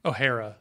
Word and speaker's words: O'Hara: Did I say her name right? Catherine O'Hare? – O'Hara, sorry.